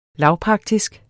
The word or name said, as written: lavpraktisk